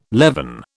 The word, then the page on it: leaven: British English